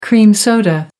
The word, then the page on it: cream soda: American English